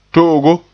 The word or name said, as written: to'ogo